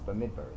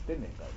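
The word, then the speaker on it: Bemidbar: The Sefer Reishis, Shmos, Vayikra, and Devarim, which are pronounced properly, but Bamidbar is not Bamidbar. It's Bimidbar, not Bamidbar.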